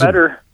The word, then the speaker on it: Meador: Is Meador.